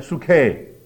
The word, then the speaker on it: psyche: 英文的翻译是从希腊文来的，英文的 psychology, psyche, 或者是如果你照希腊文发音应该 suke, 这个字就是圣经里面讲的魂，也是今天心理学所研究的那个人的魂的问题。